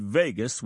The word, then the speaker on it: vagus: The word vagus, which is pronounced like Vegas, is Greek for wanderer, and wonder this nerve does.